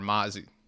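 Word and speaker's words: Mazi: I know in round one they take, is it Mozzie Smith or Mazie Smith? I never know how to pronounce his first name. – It's the former Mozzie.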